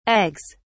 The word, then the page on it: eggs: British English